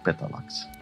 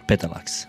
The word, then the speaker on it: Petalax: Var det korrekt uttalat eller? – Ganska bra. – Hur skulle du sagt? – Petalax. – Petalax. Ja. Det är liksom betoningen där som man alltid är fel som svensk tungad.